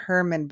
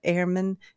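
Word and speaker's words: Hermann: It was not Herman, but Airman.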